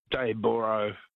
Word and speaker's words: Dayboro: It's spelled O-R-O through me. – It is. – Yeah. It should be Daybra, B-R-A-H. – Yeah. Everyone south of the border calls it Dayboro, but yeah, fair enough.